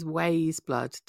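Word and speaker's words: Weyes Blood: So any listeners who aren't familiar, it reads as Wiseblood, Weyes, but it's pronounced Wise, Blood.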